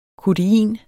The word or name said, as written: kodein